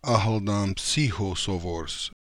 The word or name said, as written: Akhaldanpsychosovors